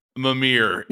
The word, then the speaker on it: Mimir: Who is the Moomer from God of War? That's a real Moomer move. Did I say it right? – No, you said it funnier. Oh. It's good. Mimir. – Mimir. Sorry.